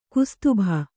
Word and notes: ಕುಸ್ತುಭ: kustubha

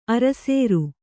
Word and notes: ಅರಸೇರು: kannada

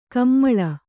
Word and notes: ಕಮ್ಮಾಳ: Kannada

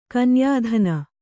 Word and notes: ಕನ್ಯಾಧನ: kanyādhana

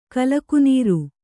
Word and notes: ಕಲಕುನೀರು: kalakunīru

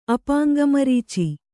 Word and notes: ಅಪಾಂಗಮರೀಚಿ: Kannada

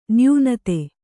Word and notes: ನ್ಯೂನತೆ: Kannada

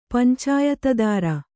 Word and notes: ಪಂಚಾಯತದಾರ: kannada

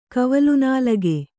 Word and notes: ಕವಲ್ನಾಲಗೆ: kavalñlage

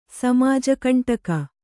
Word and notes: ಸಮಾಜಕಂಟಕ: samāja kaṇṭaka

